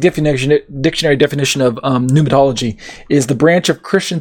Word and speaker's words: pneumatology: And when we talk about pneumatology or pneumatology, if you want to pronounce the P, um, give me a second.